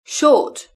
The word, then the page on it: short: British English